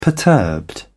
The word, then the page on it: perturbed: British English